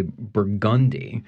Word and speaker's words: Burgundy: So there's just a shift in from the French Bourgogne into Burgundy because you have the emphasis on that second syllable in the French, and it's just a slide from the French into the English pronunciations.